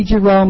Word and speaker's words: Geroux: My name is Kathy Giroux. My last name is spelled G-E-R-O-U-X.